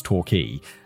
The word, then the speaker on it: Torquay: It's spelled Torquay, but I'm pretty sure it's Torquay.